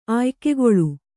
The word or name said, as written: ಆಯ್ಕೆಗೊಳ್ಳು